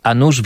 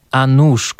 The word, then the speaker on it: nuż: We frazeologizmie a nuż słówko nóż zapisywane jest wprawdzie przez u otwarte, ale oczywiście wymawia się je tak samo jak nóż przez o z kreską, czyli przedmiot służący do krojenia.